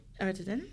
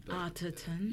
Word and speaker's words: Arterton: Arterton. – Yeah, we've seen her around a lot. – We've seen her in... – I mean, I'd say Arterton. – Arterton. – You know. – Arterton.